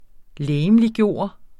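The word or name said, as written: LEGEMLIGGØRE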